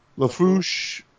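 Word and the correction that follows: LeFou: Huh? – LaFou, I think. I think it's just LaFou.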